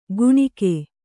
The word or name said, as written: ಕುಣಿಕೆ